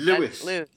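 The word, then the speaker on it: Lewes: And yeah, in Lowe's. – Yes, Lowe's? Can't forget that. – Lewis. – Lewis. – Lewis. I knew I was pronouncing it wrong.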